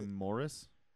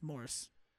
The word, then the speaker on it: Morse: Yeah, we just watched the beginning of that bit and the Morse code bit and the. – What did you say, Morris? – Morris, it's Morse.